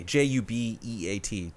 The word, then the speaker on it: Jubeat: And it's I think it's pronounced Ubeat, but it's J J U B E A T Jubeat.